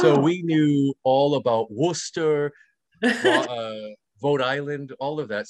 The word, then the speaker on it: Worcester: No, it's not Worcester, it's Worcester.